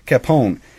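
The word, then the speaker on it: Kapaun: And so that's Capon. Capon. I don't know how to pronounce it. – Capon.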